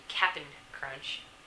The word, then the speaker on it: Cap'n Crunch: So Captain Crunch hadn't had it. Excuse me, Cap'n Crunch.